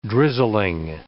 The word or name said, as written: drizzling